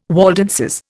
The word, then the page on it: Waldenses: Indian